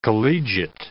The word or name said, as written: collegiate